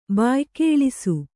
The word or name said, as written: ಬಾಯ್ಕೇಳಿಸು